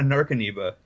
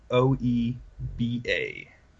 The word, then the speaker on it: Narcomoeba: Now you've got a Narcaneba in your deck, so it comes out for free. – Wait, a Narca... How do you spell it? Narca... – Omoeba. NARCOMOEBA.